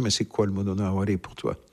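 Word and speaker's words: mono no aware: Le Monono Aware, oui, tout à fait.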